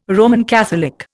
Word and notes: Roman Catholic: Indian

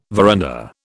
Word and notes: veranda: British English

